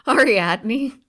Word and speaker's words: Ariadne: On the way home, Theseus abandoned how do you say that bra's name? – Ariadne. – Ariadne. It does not look like that, all right? – I know.